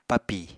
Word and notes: papi: Apatani

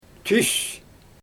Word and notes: tys: Xyzyl

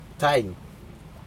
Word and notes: taiN: Aneityum

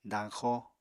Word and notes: dañxo: apatani

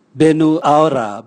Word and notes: benuaura: Raga

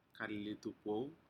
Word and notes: kalilitupou: Aneityum

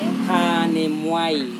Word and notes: hanemuai: Serewen

